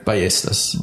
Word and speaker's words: Ballestas: Is it Ballestas? Am I saying it right? – Ballestas.